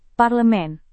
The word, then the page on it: parlemen: Indonesian